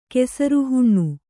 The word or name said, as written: ಕೆಸರುಹುಣ್ಣು